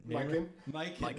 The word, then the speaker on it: Mikan: Coinciding with the Lakers being born, the team also acquired George Mikan. Did I say his name? – Mikan.